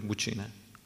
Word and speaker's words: buccine: si pronuncia così, buccine, conosciute come gomuca, e suonarono flauti e strumenti a corda.